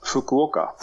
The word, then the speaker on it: Fukuoka: Vi fortsätter till Fukuoka, om jag uttalat rätt. – Fukuoka. Ja.